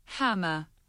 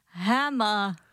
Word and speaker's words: hammer: Chytrá umělá inteligence, nebo možná už netolik umělá, říká Hammer. – Hammer. Zkus to vyslovit. – Hammer. – No vidíš to, jak je to krásně jde. Ani tam není to R.